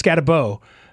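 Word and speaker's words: Skattebo: Is it Scataboo? Yeah, it's Scataboo.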